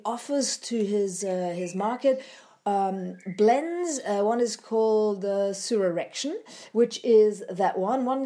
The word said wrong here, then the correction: Resurrection: This particular one, Resurrection, what did I say before? I probably mispronounced that.